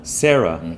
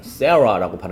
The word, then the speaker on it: Sarah: 근데 여기 두 개, 사람 이름이 특히 우리말로 표기하는 거랑 미국인들이 발음하는 거랑 달라요. 사라가 아니고 뭐죠? – Sarah. – Sarah라고 발음하셔야 되고요.